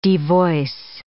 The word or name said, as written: devoice